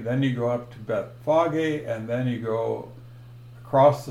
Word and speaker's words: Bethphage: If we look at Mark 11, 1 and 2, now when they drew near Bethlehem to Bethphage, and that's proper pronunciation, most people say Bethphage or something like that, or Bethphage. Bethphage is correct,